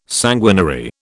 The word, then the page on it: sanguinary: British English